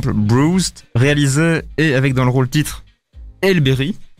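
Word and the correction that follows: Halle Berry: Alberry, là qu'on prononce. Alberry.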